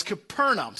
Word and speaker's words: Capernaum: I refer to it as Capernaum. Pastor Aaron refers to it, pronounced correctly, as Capernaum.